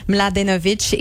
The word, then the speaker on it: Mladenovic: Ce sera un match à voir demain contre la Française Kristina Mladenovic et la Chinoise Guo Hangyu.